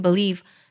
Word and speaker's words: believe: 我读的时候呢，会把 believe believe 这个单词读成 believe。美音当中呢，会把 b 这个音节读作 b 的音，这是元音的弱读。英音当中就会读的比较重，读作 believe。They're both right，两个都是对的。那么这句歌词呢也比较好唱。